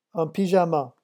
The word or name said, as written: un pyjama